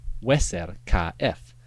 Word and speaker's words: Userkaf: For example, the name Userkaf should be pronounced Weser Ka F, meaning powerful or vital is his Ka.